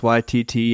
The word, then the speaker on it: Waititi: This was written and directed by Taika Waititi. I hope I'm pronouncing that right. Am I pronouncing that right? – You down with Waititi?